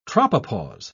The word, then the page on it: tropopause: British English